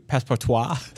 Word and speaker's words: Passepartout: Passepartout, that's French. – Okay, so Passepartout is this wonderful... Passepartout? Passepartout? – The T is silent.